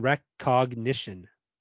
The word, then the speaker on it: recognition: I'm having trouble saying recognition. That's how you say it. Recognition. – You got it. – I was putting a K in there.